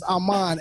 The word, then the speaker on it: Amon: My name is Amon, A-M-O-N.